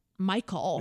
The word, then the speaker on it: Michael: Like I was calling him Mikhail. Mm-hmm. Anyways, his name is spelt Michael. – Yeah, yeah. – Like, very, it's like every other person who spells the name Michael, mm-hmm. right? It's M-I-C-H-A-E-L. – Perfect.